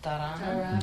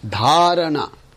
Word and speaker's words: dharana: Dharana. – Dharana. Again, not dharana. – Okay. – It's dharana.